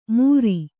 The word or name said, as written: ಮುರಿ